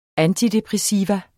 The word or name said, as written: antidepressivum